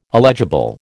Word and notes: illegible: American English

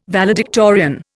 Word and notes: valedictorian: Indian

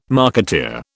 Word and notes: marketeer: British English

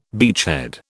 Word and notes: beachhead: British English

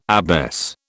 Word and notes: abbess: British English

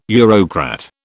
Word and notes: Eurocrat: British English

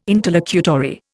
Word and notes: interlocutory: Indian

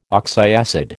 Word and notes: oxyacid: American English

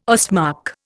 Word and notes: Ostmark: Indian

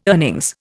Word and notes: earnings: Indian

